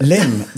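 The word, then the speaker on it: Lympne: Lim, that's right.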